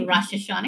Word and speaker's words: Rosh Hashanah: No, I'm not going to say Rosh Hashanah because I'm going to say it wrong.